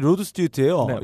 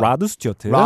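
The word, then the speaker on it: Rod Stewart: 바로 아니 로드 스튜어트예요. – 네, 영국식 발음입니다. 아, 라드 스튜어트. 라드는 영국 미국식이고요. 로드 스튜어트예요.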